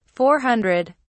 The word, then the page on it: 400: British English